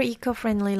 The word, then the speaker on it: eco-friendly: I dare say they prefer eco-friendly lifestyle. Eco-friendly lifestyle. 에코 아니고 이코고요.